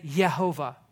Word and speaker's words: Jehovah: Or as they would say, Yehovah or Yehovah, which appeared when Christian scholars took the consonants of Yahweh and pronounced it with the vowels of Adonai. This resulted in the sound Yehovah, which has a Latinized spelling of Jehovah.